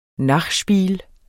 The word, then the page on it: nachspiel: [ ˈnɑχˌʃbiːl ]; [ ˈnɑχˌsbiːl ]